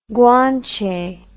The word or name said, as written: Guanche